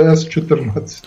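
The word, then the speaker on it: С14: Інакше читається Січ, але С-14.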